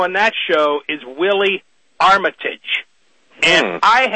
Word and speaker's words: Armitage: Richard Armitage, the gentleman you're talking about, who does have this history going way, way back, and his name is, it's pronounced Armitage, but it's spelled, it's, everybody pronounces it, it's, it looks and it looks to be like it's pronounced Armitage, A-R-M-I-T-A-G-E, but he pronounces it Armitage.